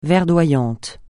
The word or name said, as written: verdoyant